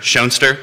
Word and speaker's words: Schoenster: And if you've been driving around Fluvanna, you've probably seen my signs that say Nature, Nurture, Schoenster. It's not only a great way to help people pronounce my name correctly, which Jerry successfully managed, and thank you.